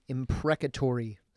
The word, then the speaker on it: imprecatory: The imprecatory psalms. – Imprecatory. – Yes. – Right. And so that means God's, or David, crying out against his enemy. – Yeah. – Can we worship and pray an imprecatory psalm? – 110%, but.